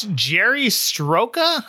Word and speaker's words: Sroka: It's Jerry Sroka, I believe it's how it's pronounced. S-R-O-K-A. Jerry Sroka, I'm going with.